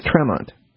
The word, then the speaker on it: Tremont: But and it's spelled Tremont. It's pronounced Tremont.